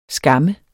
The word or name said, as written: skam